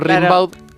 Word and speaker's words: Rimbaud: Gimbaud. – Ahí va. Claro, como se escribe, se pronuncia Rimbaud, pero es Rimbaud.